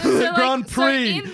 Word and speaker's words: Grand Prix: Grand Prix. You don't pronounce the X.